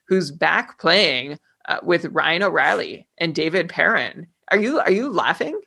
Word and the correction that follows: Perron: I'm laughing at these pronunciations. It's Blay and it's David Perron.